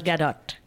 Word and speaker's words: Gal Gadot: Is that how you pronounce Gal Gadot. – Gadot. – The T is, yeah. – It's not silent.